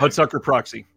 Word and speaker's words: Hudsucker Proxy: How do you say that now? Hudsucker Proxy. – There we go.